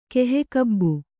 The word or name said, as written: ಕೆಹೆಕಬ್ಬು